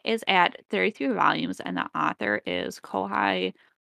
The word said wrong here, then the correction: Kohei: Horikoshi. I think I said his name correctly. – Yes. Oh, okay. Kohei. I would say Kohei, Kohei Horikoshi. My bad.